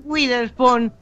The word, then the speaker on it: Witherspoon: Reese Witherspoon.